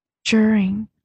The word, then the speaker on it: during: 那正确的发音其实是 during，是 j 的音。